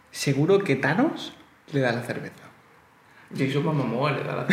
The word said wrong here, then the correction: Momoa: ¿Cómo se dice? – Momoa. Ah, pues siempre he dicho Mamamoa.